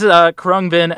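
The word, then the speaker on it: Khruangbin: Let me give this a shot. This is Krangbin. Nope. I'm looking this up. It's Krangbin. Sorry, been all around the galaxy, and this is the one thing I can't pronounce. Kind of embarrassing. But anyway, this is Krungbin and Leon Bridges with Texas Sun.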